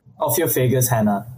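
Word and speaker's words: Ophiophagus hannah: Ophiophagus hanna.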